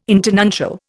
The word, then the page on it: internuncial: Indian